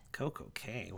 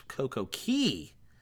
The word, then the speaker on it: Coco Cay: It's Coco Key. It's Royal Caribbean. We don't pronounce things the way the Bohemians pronounce it. Sorry. Oh, no. You said it right. My bad. Coco Cay. You're right.